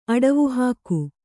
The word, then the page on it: ಅಡವುಹಾಕು: Kannada